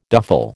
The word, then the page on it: duffle: American English